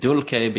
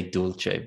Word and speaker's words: dulce: And I'm saying dulce because I use the classical Latin pronunciation. So if you're a medieval Latin speaker, you'll say dulce.